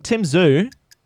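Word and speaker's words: Tszyu: Anyway, second topic, Tim Zhu. That's how you pronounce it, of course.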